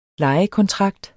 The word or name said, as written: LEJEKONTRAKTER